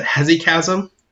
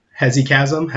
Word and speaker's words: hesychasm: is another term I'm going to mispronounce, I'm sure, but hesychasm, H-E-S-Y-C-H-A-S-M, hesychasm, hesychasm.